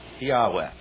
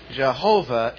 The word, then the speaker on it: Yahweh: Number one, I assume that the only one true God in the Old Testament is called Yahweh. Y-A-H-W-E-H. I'll put parenthesis, Jehovah. Jehovah is a mispronunciation of God's name.